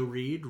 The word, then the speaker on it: Reid: I am on Twitter at Joe Reed. Reed is spelled R-E-I-D.